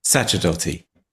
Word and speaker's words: Sacerdoti: Sacerdoti.